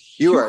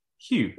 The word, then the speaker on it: Hugh: Hugh, Hefner. – De, Hugh Hefner. Así pronunciamos los que hablamos inglés británico. Hugh.